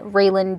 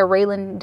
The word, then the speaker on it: Raylyn: or you can send me a DM at Raylan Dunn, or find me on Facebook at Raylan Dunlap. My name is spelled R-A-Y-L-Y-N.